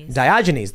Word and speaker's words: Diogenes: How do you pronounce that? – Diogenes, the dog, says, I think you're right, Tim.